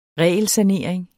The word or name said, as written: regelsanering